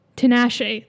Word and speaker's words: Tinashe: That's probably what their motive with Tinashe. That's how you pronounce her name.